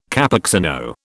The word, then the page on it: Cappuccino: British English